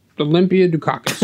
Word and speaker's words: Olympia Dukakis: Olympia Dukakis.